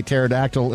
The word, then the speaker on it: pterodactyl: Brian said, why can't you hear a pterodactyl in the bathroom? – Why? – Because the P is silent.